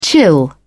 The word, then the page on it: chill out: British English